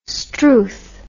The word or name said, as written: strewth